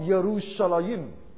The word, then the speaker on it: Yerushalayim: Like in Hebrew, it's not pronounced as Jerusalem. It's called Yerushalayim. It's Y.